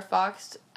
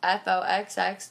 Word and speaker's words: Foxx: Twitter, you know, my Twitter is at Stevie underscore Foxx, Foxx, 2 X's.